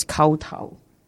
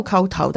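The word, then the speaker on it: kowtow: And I said, in Cantonese, this is kao tao.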